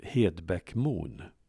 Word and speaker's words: Hedbäckmon: som Hedbäckmon. Namnet uttalas med tydligt e-ljud fast ordet hed uttalas hi i Dalby.